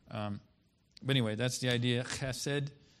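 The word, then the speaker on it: chesed (חֶסֶד): Because Hebrews have a lot of words that are they're called gutturals. So they're pronounced like from the throat. Like when you're getting ready to spit. So this word is chesed.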